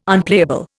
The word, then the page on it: Unplayable: Indian